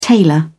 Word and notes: tailor: British English

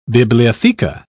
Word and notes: bibliotheca: British English

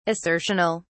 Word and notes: assertional: British English

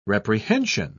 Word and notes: reprehension: British English

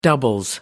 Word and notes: doubles: British English

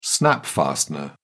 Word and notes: snap-fastener: British English

